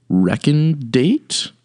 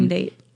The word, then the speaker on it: recondite: It is recondate, I believe is the pronunciation, but it's spelled recondate. – R-U-C-O or R-E-C-O-N-D-I-T-E.